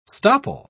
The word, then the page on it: stopple: British English